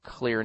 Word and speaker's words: Xlear: And right after that, Lydia, you're going to go into your sinus cavity with something called clear nasal spray, and that is spelled X-L-E-A-R. You